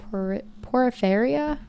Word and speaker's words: porphyria: Porphyria? P-O-R-P-H-Y-R-I-A. How do you say that? – P-O-R-P-H. Porphyria? – Y-R-I-A.